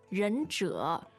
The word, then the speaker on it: 忍者: But in Japanese, it's pronounced ninja. And in Chinese, it's pronounced 忍者.